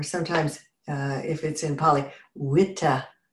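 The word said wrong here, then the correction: Vitakkasanthana: Oops, I just screwed it up. The V is pronounced W. Vittakasatana. It's V I T A K K A S A N T H A N A.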